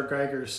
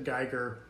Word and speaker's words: Giger: And I'm going to get in trouble here because I've always pronounced it Giger, but in the documentary, everyone pronounced it Giger. So I guess I had it wrong.